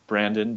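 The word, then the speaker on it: Brandan: And then I am a Twitter addict, so follow me there at Brandon, B-R-A-N-D-A-N, J-R.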